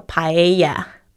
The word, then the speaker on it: paella: 其实在西班牙就是叫 paella, paella.